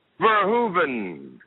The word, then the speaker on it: Verhoeven: Verhoeven.